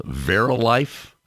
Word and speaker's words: Verilife: Verilife? Am I pronouncing that correctly? Sure.